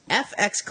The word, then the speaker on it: EFX: Now, also, a company that we're familiar with for their Star Wars products, as well as Battlestar Galactica and some others, FX Collectibles. That's how you pronounce it if you're new to this company. EFX is how they spell it, but EF is pronounced F and then X.